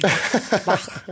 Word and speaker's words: Bach: Bach.